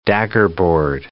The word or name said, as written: daggerboard